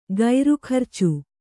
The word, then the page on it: ಗೈರುಖರ್ಚು: gairu kharcu